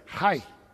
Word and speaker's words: Ai: Now, if you want to know how the Hebrew people pronounce Ai, it's kind of funny. It's Hai. Or it's almost like I, like a Canadian, Ai. But you put a little bit of an H at the beginning of it.